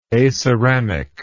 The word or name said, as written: aceramic